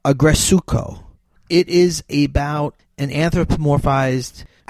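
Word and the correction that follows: Aggretsuko: The lead character's name is Retsuko. So it's called Agretsuko because it's about this character, Retsuko, who she's a red panda.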